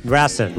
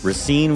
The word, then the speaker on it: Racine: Rassin. Rassin. – Nope. We're going to say it right. We're going to respect the people of this town. Racine, Wisconsin was a beautiful little town on Lake Michigan.